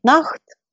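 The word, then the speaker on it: nacht: На голландском это звучит «нахт», «ночь». Буквы «сх» произносятся в современном голландском как «х».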